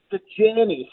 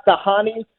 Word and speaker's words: Dajani: As long as you're saying the J, like not Dajani, we're going to be okay. I think the way it is, and it's Arabic, by the way.